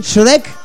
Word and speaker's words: Shrek: Shrek, ahí está.